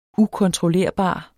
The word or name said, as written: ukontrollerbar